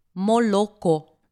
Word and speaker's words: молоко: Молоко. – Да, все верно. Здесь три буквы «О». Однако по-русски мы говорим «молоко». Написано «О», а произносим мы «А».